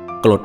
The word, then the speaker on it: กลด: สวัสดีครับพบกับรายการคำวัดสำหรับวันนี้เสนอคำว่ากรด